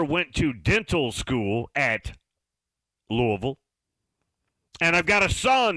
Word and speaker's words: Louisville: Again, I implore you, get the pronunciation right. It's not Louisville, it's Louisville. You got to go with the locals, Louisville.